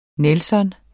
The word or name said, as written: nelson